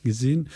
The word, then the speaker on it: Gysin: Ich glaube, so einige der wichtigen waren Bernard Eitzig und Brion Gysin. Spricht man das so aus? – Gysin.